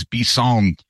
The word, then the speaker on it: bison: And I learned that the French for bison is bison. – That would totally make sense. – Yeah, it's bison. It's the same spelling, just different. – Yeah, it's just bison. – The emphasis on the final syllable in the French way.